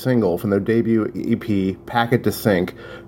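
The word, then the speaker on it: Paquet de Cinq: Just so you know how to spell that, it's P-A-Q-U-E-T, one word, capital D-E, second word, Sync, C-I-N-Q, Packet to Sync.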